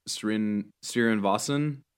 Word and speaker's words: Srinivasan: And it really is interesting, and it's kind of why I decided to open up the article with Balaji, I'm going to butcher his last name, Balaji Srinivasan. – Sounds right to me. – Yeah, that's close enough.